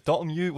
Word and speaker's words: Dotemu: Yeah, you know, surprisingly, the Dotemu was the bit you got right.